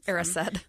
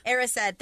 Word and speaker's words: Erised: And when he's in front of that mirror, which reminds me of the Mirror of Desire, of Ezered, however you pronounce it. – Erised. – Erised, thank you.